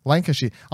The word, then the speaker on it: Lancashire: Arriving in 17th century Lancaster... Lancashire? How do you say that word? – Lancashire.